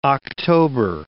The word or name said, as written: October